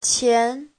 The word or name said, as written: qian2